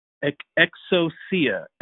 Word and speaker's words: exousia: Well, in order to understand what that means and what Jesus was actually telling his church and telling his disciples, it comes from the word exousia, that's how you say it, E-X-O-U-S-I-A.